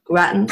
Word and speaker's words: Grattan: Another interesting idea is when Anthro tells Jack about the punkies, or is it the Grattan? Am I pronouncing Grattan or Grayton? How would you like to pronounce that? – Grattan. – The Grattan.